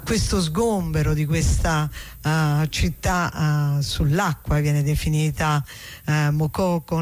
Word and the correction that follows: Makoko: Makoko, sì, adesso non so se con l'accento finale alla francese è Makoko, però probabilmente siccome quello è un paese anglofono sarà in maniera diversa.